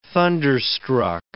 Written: thunderstruck

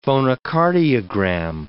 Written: phonocardiogram

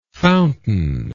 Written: fountainlike

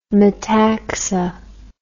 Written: metaxa

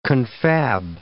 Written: confab

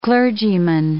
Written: clergyman

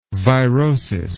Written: virosis

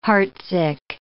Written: heartsick

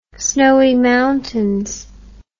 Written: Snowy Mountains

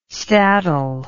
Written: staddle

